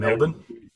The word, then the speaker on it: Melbourne: So Pronunciations we, of Melbourne are perfect, by the way. I love the Australian Melbourne.